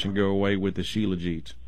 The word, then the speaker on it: shilajit: Yeah, and so I think it's spelled S-H-I-L-A-J-I-T, but I think you pronounce it shilajit.